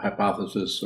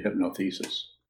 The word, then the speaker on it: hypothesis: So it's kind of an operating temporary hypothesis, or as I called it when I was in 10th grade, a hypnothesis. I didn't know how to pronounce it.